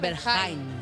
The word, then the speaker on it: Berghain: Chicos, no queda una sola persona en España que no haya expresado en público su opinión sobre Berghain. ¿Cómo se pronuncia? – Berghain. – Berghain. – Ah, es Berghain, no es Berghain. – Es alemana.